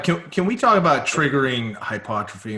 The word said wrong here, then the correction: hypertrophy: and what it takes? – Hypertrophy? – Yeah. – Hypotrophy. – How do you say? Hypertrophy. – Or hypertrophy. I always have a problem with that word, hypertrophy.